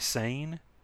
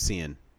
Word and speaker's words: Seyn: Because in the book, it's spelled Seyn. So I would always say Sane. – No, it was Sin. – Is Sin? – Yeah, Sin. – Sin. Gotcha. Okay. So yeah. – So yeah, I was wondering about that. – At least that's the audiobook pronounced it Sin.